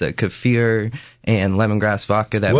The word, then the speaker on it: kefir: Kefir.